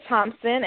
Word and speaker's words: Thompson: Just search for Tanya Thompson, and I will pop up.